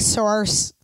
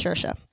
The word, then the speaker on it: Saoirse: Well, it looks like Source, but it's Saoirse Ronan in Little Women.